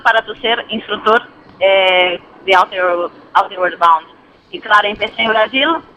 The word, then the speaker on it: Outward Bound: Esa era una cuestión que también que queríamos preguntarte, lo que nos acabas de comentar, corrígeme, porque mi pronunciación tampoco es que sea muy, muy buena, Outward Bound, ¿no? – Sí. – Se trata. – No, que lo diga ella, que lo dice mejor. ¿cómo se dice? – Sí, es una escuela que se llama Outward Bound. – Igualito, ven.